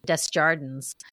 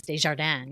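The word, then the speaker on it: Des Jardins: I think she grew up outside Chicago, and I believe she said that for a while her parents pronounced it Desjardins or something like that. And now, of course, the proper French pronunciation is Desjardins, you know, which is so much prettier too, but no offense.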